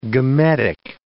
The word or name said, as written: gametic